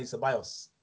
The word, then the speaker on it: Ceballos: He's still in living, and putting Dani Ceballos.